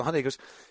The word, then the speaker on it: Hyundai: I was like, I was terrified I was going to mispronounce Hyundai. Like, I looked up, like, how do you pronounce Hyundai? Is it Hyundai? Is it Hyundai? Like, how do you pronounce Hyundai? And it's like, Sunday.